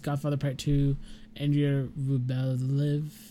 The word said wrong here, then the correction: Andrei Rublev: Andrei Rublev, Raging Bull, uh, that last one I couldn't pronounce.